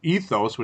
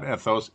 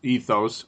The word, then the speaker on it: Ethos: If you like something with even more, uh, you know, zing per se from from hops, that would be Ethos, which I now am pronouncing correctly, not Ethos, Ethos IPA, which is the newer of the two.